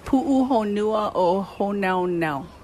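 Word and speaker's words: Pu'uhonua o Honaunau (Puʻuhonua o Hōnaunau): Pu'uhonua o Honaunau.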